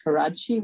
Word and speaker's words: Karadsheh: Just for the benefit of the audience, it's pronounced Karachi, which is again a very complicated last name.